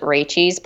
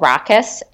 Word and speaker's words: Rachis: It looks like Rachi, like you would pronounce it Rachis, but it's called Rakus, and it's a Sauvignon Blanc.